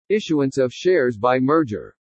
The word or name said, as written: ISSUANCE OF SHARES BY MERGER